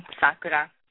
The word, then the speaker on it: Sakura: My whole name is Allison Tamie, and then Sakura Yahata.